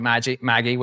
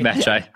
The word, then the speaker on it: Magi: Project Maggie, which is this Magi. It makes more sense. – It's Magi. It's okay. Everybody watching just know pronounces everything wrong.